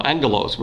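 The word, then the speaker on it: Angelos (ἄγγελος): The angel, angelos. Remember, when you got 2 G's in the Greek, it's pronounced with an N. Angelos.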